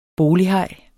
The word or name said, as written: BOLIGHAJER